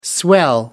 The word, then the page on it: swell: American English